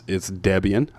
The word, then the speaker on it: Debian: But, and for those of you all that have used Linux or are somewhat familiar with Linux on Chrome OS, it's Debian.